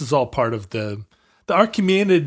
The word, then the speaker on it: Achaemenid: How is that pronounced? Archaemenid? – Achaemenid. I've never had to say it. – Oh, it's it doesn't have an R. Achaemenid. Achaemenid.